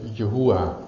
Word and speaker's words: Yahweh: Nou, een tijd lang is gezegd van dat is waarschijnlijk uitgesproken als Yahweh. Het kan ook zijn dat het Yahuwah moet zijn eigenlijk. Omdat die W eigenlijk ook een U of een OE klank kan zijn.